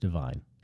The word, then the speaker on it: DVYN: It's DVYN, pronounced Divine.